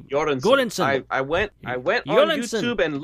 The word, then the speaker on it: Göransson: It's a Swedish word, and the G is pronounced with a Y sound. Göransson.